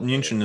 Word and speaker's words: München: Oh, well, München is how you say Munich in German.